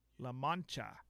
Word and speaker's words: La Mancha: La Mancha. That's how it's said.